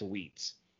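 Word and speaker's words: Uitz: It's spelled U-I-T-Z. I think that's Wheats.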